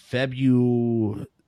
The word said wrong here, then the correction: Febuxostat: but it's with an X. I think it's a Zo sound with us. So it's Febuxostat.